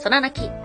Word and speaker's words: 空亡: それは、百鬼夜行絵巻の最後に現れると言われている、空泣き。別の名前を空房と言います。空っていうのは、まあ、太陽が上がる空なんですが、それになくなるという字で、空泣きと読みます。